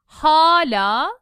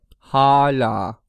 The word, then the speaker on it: hala: Hala, the first one is long, and the second one is fronted. – Hala.